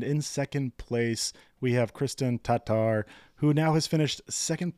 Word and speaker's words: Kristen Tatar: And yes, we are going to attempt to pronounce it correctly now moving forward. Kristen Tatar. Hopefully that's a little bit closer for you, Kristen.